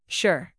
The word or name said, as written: sure